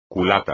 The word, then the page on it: culata: espagnol